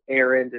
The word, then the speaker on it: Arend: And to follow me, my name is Arend. It's spelled A-R-E-N-D, Richard.